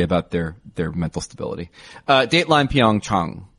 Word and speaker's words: Pyeongchang: It's Pyeongchang. That's the pronunciation.